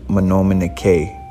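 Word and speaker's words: manoominike: And in Ojibwe, that's pronounced Menominike.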